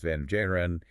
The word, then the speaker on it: Van Buren: His name, pronounced Van Jaren, and his family's ancestry was entirely Dutch, tracing back to the earliest European settlers in that region.